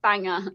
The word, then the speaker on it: Bangor: Just for the Americans watching here, Bangor is the name of the town. That is how it's pronounced. – It's spelled Bangor, yes. But like, yes. Yes, it's pronounced Bangor.